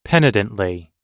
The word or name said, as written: penitently